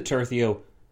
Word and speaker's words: tercio: My pronunciation of this word has gone from tercio to tercio to tercio to tertio, so it's going all over the place. But now we've landed on tercio, which I'm told is correct.